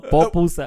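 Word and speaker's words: pupusa: I had a papusa today. Did you? Yeah, it's good from is that how you say it? No, popusa.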